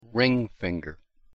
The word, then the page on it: ring finger: English